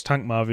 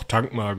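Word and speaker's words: Thankmar: Ähm, und da hatte er auch schon einen Sohn, nämlich den äh, Tankma, Tankma, wie spricht man das aus? – Tankmar, würde ich sagen, oder? Ich hätte es jetzt auch Tankmar gesprochen. Also schreibt sich mit TH, aber das ist wahrscheinlich wie in Theodor.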